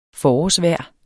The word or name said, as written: forårsvejr